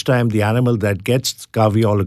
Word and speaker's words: kahweol: K-A-H-W-E-O-L, caviol. And then there's something called cafestol, C-A-F-E-S-T-O-L.